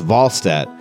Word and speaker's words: Wahlstedt: Actually, it's pronounced Wahlstedt. That's how he told us it's pronounced in Swedish during training camp.